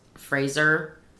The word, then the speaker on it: Fraser: He told me it's actually pronounced Fraser. – Oh. – Like laser.